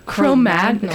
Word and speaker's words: Chromagnon: How do you say that? – Chromagnon? – Chromagnon. Kind of like Cro-Magnon Man? – Yeah, Cro-Magnon.